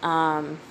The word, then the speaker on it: Becka: Becca is spelled BECKA.